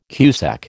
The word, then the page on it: cusec: American English